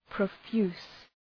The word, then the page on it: profuse: prə’fju:s